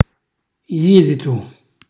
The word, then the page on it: jiditu: Calabrese